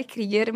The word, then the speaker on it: Kruger: O meu sobrenome é Mari Krieger, mas se escreve Mari Kruger, K-R-U-G-E-R.